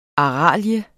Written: ARALIEN

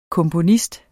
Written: komponist

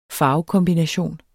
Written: farvekombination

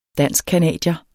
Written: danskcanadier

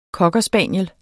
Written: COCKER

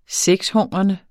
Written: sexhungrende

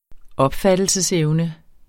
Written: opfattelsesevne

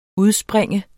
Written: udspring

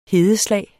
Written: hedeslag

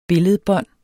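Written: BILLEDBÅND

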